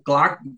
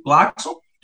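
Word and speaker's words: Klaxon: Dessas a gente pode citar a, a Claxon, ou clac como, como o senhor pronunciou, eu pronuncio Claxon,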